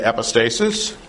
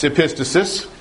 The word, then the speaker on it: epistasis: This one is epistasis, which is a word that is often mispronounced. A lot of people want to say epistasis. It's epistasis.